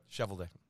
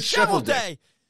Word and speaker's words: Cheveldayoff: I'm sure I'm mispronouncing his name. They were both with the Blackhawks in 20. What? – Cheveldayoff. – Cheveldick? that what you said? – No. – I thought you said Shevelday. Okay.